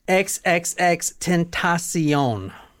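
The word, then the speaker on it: XXXTentacion: So let me pronounce it for you XXX T E N 10 Ta T A H C, like I see you, S E E, on, like Oprah's network. XXX Tentacion.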